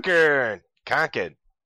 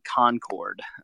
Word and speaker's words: Concord: Concord. – I was informed at the podcast taping today that is how you say it. It is not Concord. a true Englishman should say Concord.